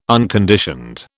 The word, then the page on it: unconditioned: British English